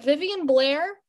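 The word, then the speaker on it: Vivien Blair: And I don't think I've talked about Leia at all in this show to do her justice, because is it Vivian Blair? – That's how you say it.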